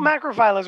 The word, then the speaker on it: macrophylla: Um, yeah, they tend to, they look more like the, um, macrophilia uh, varieties. I continue to say that wrong, don't I? Um, you... – No, macrophylla is right.